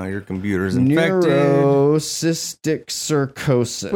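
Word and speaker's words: neurocysticercosis: Neurocystic sarcosis.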